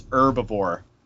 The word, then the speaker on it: herbivore: Well, in America, we were always told it was herbivore. So the H is silent here for some reason.